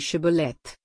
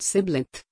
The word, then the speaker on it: Shibboleth: If he said, "No," then they said to him, "Now say Shibboleth," and he said Sibleth, for he couldn't manage to pronounce it right.